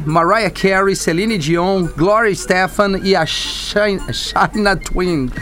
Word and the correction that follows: Shania: Adoro a Shania, Shania Twain. A, é a, é difícil esse nome.